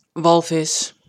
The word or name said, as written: Walvis